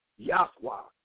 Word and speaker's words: Yahuwah: But what you're going to put in their head is the word of YHWH pronunciation, Yahuwah, in their head.